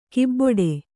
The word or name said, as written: ಕೊಬ್ಬಟ್ಟು